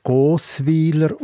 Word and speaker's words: Gossweiler: In der traditionellen Mundart von der Bürgerort der Gossweiler wird der Name sogar als Gossweiler ausgesprochen, noch mit einem langen O im ersten Glied wie bei Gossau.